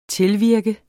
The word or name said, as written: tilvirke